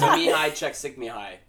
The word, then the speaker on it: Mihaly Csikszentmihalyi: Mihaly Csikszentmihalyi. – There's just no way that's right. – His name is Mihaly Csikszentmihalyi. – Csikszentmihalyi.